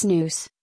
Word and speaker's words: snus: Snus, pronounced snus, is a smokeless tobacco product that originated in Sweden in the 18th century.